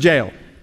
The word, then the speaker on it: Jael: By the way, it's J-A-E-L. It's pronounced four different ways. I just pronounce it Jael or Jael.